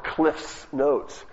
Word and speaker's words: Cliff's Notes: Cliff Notes? It's actually, I didn't say it correctly, it's called Cliff's Notes.